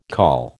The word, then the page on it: call: American English